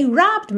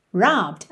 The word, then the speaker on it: robbed: Atenção que a gente não passa por esse é aí. É um verbo regular. O final dele é ed, mas o é não é pronunciado, certo? Então você não diz robbed e sim robbed. Robbed. Tá bem? Do B você já vai pro D.